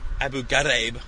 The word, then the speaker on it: Abu Ghraib: Abu Ghraib tortures, as they pronounce it, which I'm sure is correct.